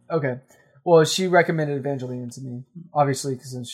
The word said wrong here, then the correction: Evangelion: Evangelion? – Yeah. – Oh, okay. – Oh, is that how you pronounce it? – No, you pronounce it correctly.